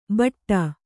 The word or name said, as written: ಭಾಟ್ಟ